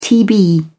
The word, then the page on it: tb: British English